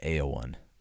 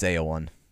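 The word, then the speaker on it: Éowyn: In Old English, the name is actually pronounced Eowyn. And the Rohan is modeled after Old English, the language at least. So it's Eowyn.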